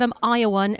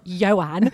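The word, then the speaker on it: Ioan: We're like, oh, we love the sound of Ayawan, as we used to call him. So obviously when we first got in touch with, I'm now forgetting how to say his name, Yowen.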